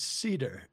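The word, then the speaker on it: Seder: How do you say it? – It's Cedar. It's Sam Cedar, yeah.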